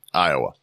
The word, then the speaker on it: Iowa: Speaking of Iowa, anybody out there, Iowa, Louisiana is spelled Iowa. – Yeah. – But if you're ever driving through Louisiana, pronounce it Iowa. – Yep. – Because that's how it's fucking pronounced.